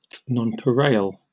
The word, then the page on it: nonpareil: British English